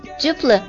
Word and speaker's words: duplicate: 接下来看看它的形容词啊，形容词它的发音变了，它读音是 duplicate， duplicate 形容词表示复制的，副本的。比如说他用一把复制的钥匙打开门进去。